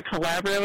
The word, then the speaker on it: Calabrio: Am I pronouncing that correctly first? I should have asked you that beforehand. – You are. Calabrio is correct.